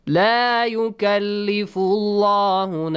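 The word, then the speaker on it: La yukallifullahu (لا يكلف الله): The word Allah, the lam in the word Allah, is pronounced heavily because there is a dhamma sound before. La yukallifullahu. Yukallifullahu, right? – Yes. – The rule says if you have a dhamma or a fatha sound before, pronounce the lam heavily.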